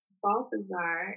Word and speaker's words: Balthazar: I'm so grateful to have had you, an expert in sleep research, at Dr. Monique Balthazar.